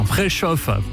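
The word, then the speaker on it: Preszow: Préchauffe. C'est, ça s'écrit Préso, mais ça se prononce Préchauffe.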